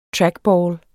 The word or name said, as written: trackball